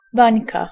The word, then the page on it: bańka: /ˈbaɲka/